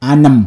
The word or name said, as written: Anim